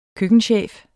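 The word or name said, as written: køkkenchef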